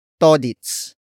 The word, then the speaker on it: todits: Todits. Todits, which is spelled as T-O-D-I-T-S.